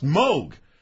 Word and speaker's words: Moog: I've heard the song 30 years ago, but we played it because the inventor of the Moog Synthesizer, whose name, ironically enough, was mispronounced through the entire period of his notoriety, his name was pronounced Moog.